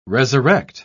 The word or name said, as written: resurrect